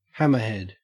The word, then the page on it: hammerhead: Australian English